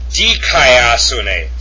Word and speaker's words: Dikaiosune: It's pronounced dukaios. Hold on a second. Dukaiosune. Oh, I'm sorry. Let me take that back. Dikaiosune. Sorry. Dikaiosune. It's got an iota. That's dikaiosune with the accent over the upsilon.